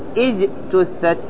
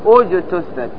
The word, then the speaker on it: اجتثت: لاحظ كلمة اجتثت، طبعا حال الوصل ما سأنطقها لأن هذا شيء معروف، فأقول خبيثة اجتثت ما في همزة، لكن عندما أقف مثلا وأريد أبتدي بأجتثت ما سأقول اجتثت، وإنما أقول أجتثت.